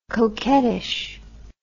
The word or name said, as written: coquettish